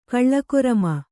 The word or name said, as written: ಕಾಲಕರ್ಮ